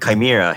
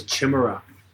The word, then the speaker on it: Chimera: It's called Chimera. Here, I'll show you the label. – Oh, that's how you say it. Okay, I thought it was Chimera, like a basic ass idiot that I am.